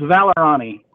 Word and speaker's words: Vallorani: it's it's Vallorani.